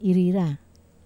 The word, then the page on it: rira: wamesa